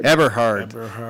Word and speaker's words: Eberhard: Eberhard.